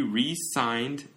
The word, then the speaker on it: re-signed: Z 발음으로 하면은 사직한다 이런 의미고 S 발음으로 하면은 이렇게 그 이거 재계약. So he re-signed S 발음으로 했잖아요.